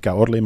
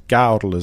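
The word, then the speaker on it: Caorle: want dat was vooral om uit te leggen dat we Kaorle toen hadden verkeerd uitgesproken. Niet Kaorle, maar het moet Kaorle zijn. – met de nadruk op de A blijkbaar.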